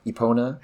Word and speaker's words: Epona: Especially when you're just waltzing around, adventuring with your horse or Epona.